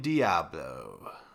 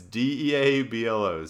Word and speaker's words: Deablo: It's D E A B L O, so I assume that's how it's pronounced.